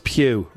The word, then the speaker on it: Pugh: Florence Pugh rhymes with 22 and Yelena Belova,